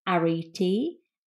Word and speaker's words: Arete: And this name is pronounced Arete, and it's spelt A-R-E-T-E.